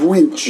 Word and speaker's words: winch: So we had pronunciation of winch. – Winch. So it's not the girl on the broom. It's the thing on the front of a Duke power truck.